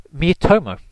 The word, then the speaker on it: Miitomo: But yeah, and but of course, the thing that I've been enjoying most is this new app called Miitomo.